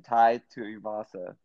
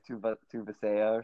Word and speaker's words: Tai Tuivasa: And now I can actually say his name, Tai Tuivasa. Remember last time I was like Tuva Tuvasea or something?